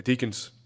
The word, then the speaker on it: Deakins: Uh, the cinematography is done by uh, Roger uh, Deacon. Uh, Deakins. De- yeah, that, yeah, that's who pronounced it. Yeah, Roger Deakins.